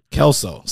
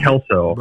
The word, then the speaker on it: Kalsow: Yeah, it's just like that 70s show or Scrubs, if you've seen those at all. They have the iconic Kalsow characters. It is spelled a little bit different. So I have been called Calzone or Coleslaw before. But no, Kalsow.